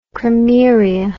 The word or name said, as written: krameria